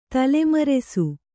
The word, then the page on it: ತಲೆಮರಸು: tale marasu